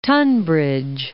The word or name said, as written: Tunbridge Wells